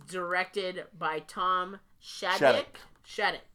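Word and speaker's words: Shadyac: That's what I've heard it pronounced. – I've said Shadyk. I've heard it both, but I think it's Shadyk. You're right.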